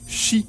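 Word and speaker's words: shi (四): C'est sûrement parce que le mot japonais pour 4 est shi. S-H-I. – Hein? Et shi, quoi? – Shi. S-H-I. – Qui est shi, là? – Ferme-la. Qui a la même appellation et une prononciation similaire au mot mort en japonais.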